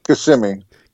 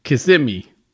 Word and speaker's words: Kissimmee: And then it's in, how do you say that? Kiss Me, Florida? Kissimmee or what? – Oh, it's Kissimmee. – Kissimmee.